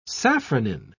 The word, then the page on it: safranin: British English